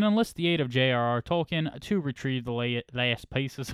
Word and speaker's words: Tolkien: So, for those of you who are offended by it, let me tell you that if he was alive today, he would prefer people to pronounce it the way they did, Tolkien. It is the proper German pronunciation, and he was a stickler for words because, you know, he's a professor of languages.